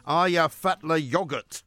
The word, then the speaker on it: Eyjafjallajökull: Aya Fatla Yogurt. Apparently, that's how you say it in Icelandic anyway. It doesn't spell like that, of course. It looks like Eyjafjallajökull.